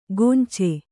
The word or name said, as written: ಕೊಂಚೆ